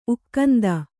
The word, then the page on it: ಉಕ್ಕಂದಿ: ukkandi